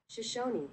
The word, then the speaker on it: Shoshone: I'm going to first explain with the Shoshan. I am not pronouncing it right. Let me look it up. – I think you might be. – Shoshone. – Okay.